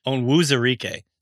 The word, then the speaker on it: Onwuzurike: So I've seen actually seen multiple different uh like the phonetic explanations for how to to say his name, but I saw on Twitter someone say Onwuzurike, like spelled it out, Onwuzurike. And then he said on Twitter, Levi Onwuzurike said correct.